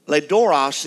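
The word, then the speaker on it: loidoros: Ledoros is the way you pronounce the Greek word, and it simply means, are you ready?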